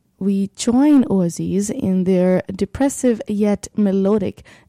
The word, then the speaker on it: Oezys: Up next, let's play this band, which is called Ooaziz. Ooaziz, it is spelled like O-E-Z-Y-S, is a gloom metal band from Cincinnati, Ohio.